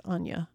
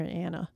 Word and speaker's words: Anya: I was pronouncing her name wrong. It's Anya. I think I was calling her Anna.